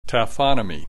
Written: taphonomy

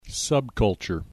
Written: subculture

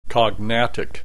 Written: cognatic

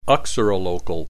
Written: uxorilocal

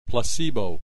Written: placebo

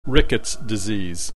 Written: Rickets Disease

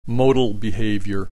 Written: modal behavior

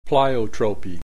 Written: pleiotropy

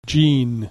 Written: gene

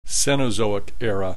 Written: Cenozoic Era

